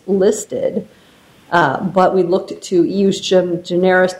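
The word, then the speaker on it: ejusdem generis: Well, so here's my question for you, which is in SGI and in sports graphics, they relied on, and I'm going to pronounce this wrong, despite my clerk actually giving me the phonetic pronunciation, eustem generis. – Eustem generis, yes, eustem generis, all right.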